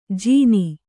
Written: ಜೀನಿ

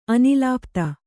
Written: ಅನಿಲಪಥ

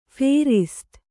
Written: ಫೇರಿಸ್ತು

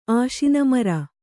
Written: ಆಶಿನಮರ

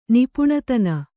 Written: ನಿಪುಣತನ

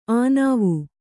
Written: ಅನುವು

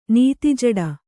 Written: ನೀತಿಜಡ

